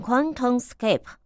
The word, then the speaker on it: QuantumScape: 好，这个名字呢，应该是念成 QuantumScape 啊。